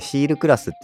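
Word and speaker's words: sealed class: で、今、あの、菱玉さんのサイト見て、あの、わかったんですけど、シールドクラスじゃなくて、シールクラスっていうらしいですね。 – ああ、なるほど。シールドは、シールドクラスって書いて、シールクラスって読むみたいですね。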